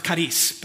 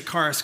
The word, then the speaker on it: charis: The Greek word that's translated favor here is the word um, It's, it's, pronounced "karis," but I'm going to say charis because I'm a Westerner and I don't want to sound uh, weird. So, "karis," charis, "karis."